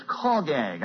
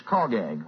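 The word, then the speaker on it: chagag (חגג): It's pronounced in Hebrew as chagag. I said hogag last night. It's chagag. Well, I don't know if that makes any difference to anybody, but that's how you pronounce it.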